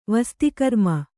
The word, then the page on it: ವಸ್ತಿಕರ್ಮ: vasti karma